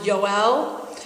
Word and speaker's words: Yoel: Joel, it's in English, it's Joel, but there are no J's in Hebrew, so it would be pronounced Yoel.